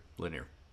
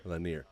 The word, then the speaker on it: Lanier: Yeah, the government, in their determination to create Lake, how do you say it? Lanier. – Lanier. Yep. – Lanier.